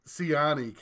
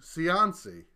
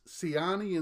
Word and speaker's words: Ciani: If you recall from our last episode, I had sort of an accusatory tone when the name Suzanne Ciani came up. I accused you of butchering her name to Ciancy.